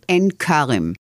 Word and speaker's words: Ein Kerem: The village I grew in is south of Jerusalem, and it's called Ein Kerem.